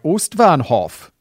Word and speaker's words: Ostbahnhof: With German pronunciation, I tend to just say it in a German accent, like Ostbahnhof. That sounds more Dutch. Ostbahnhof. Maybe. This was advice, and I was like, doesn't it just sound a bit racist? And people were like, no, but it does actually sound closer to the real pronunciation.